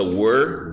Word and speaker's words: were: Não confunda were, W-E-R-E, com where, que é onde. Quando eu pergunto where are you from? Where are you from? W-H-E-R-E.